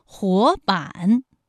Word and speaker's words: 活版: 活版，第一个字是二声，第二个字是三声。